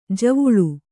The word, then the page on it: ಜವುಳಿ: Kannada